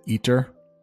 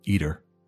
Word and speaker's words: eater: I actually pronounce that T, but when you're really just kind of talking to your friends, that T becomes a D, eater, eater.